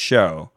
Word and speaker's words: shew: All my life, I've said like, oh, and he shoe unto them, you know, like ye is not pronounced you, but it turns out S-H-E-W is pronounced show.